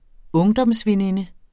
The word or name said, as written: ungdomsveninde